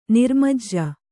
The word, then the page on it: ನಿರ್ಮಜ್ಜ: kannada